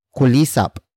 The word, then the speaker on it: kulisap: It is an object marker that introduces what they have been catching, and it is kulisap.